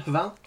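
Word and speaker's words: Hval: Yeah, that's pronunciation, Hvall.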